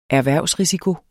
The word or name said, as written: erhvervsrisiko